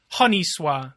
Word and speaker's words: Honi Soit: Yeah, I think most people uh, use a hard H, but yes. – Honi Soit.